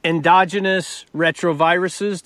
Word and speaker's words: endogenous retroviruses: Endogenous retroviruses.